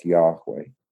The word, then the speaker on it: Yahweh: That would tell us that that it was pronounced Yahweh.